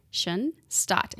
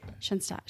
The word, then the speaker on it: Schoenstatt: But German word for beautiful place, German S-C-H-O-E-N, Shunstot, S-T-A-T-T, Shunstot.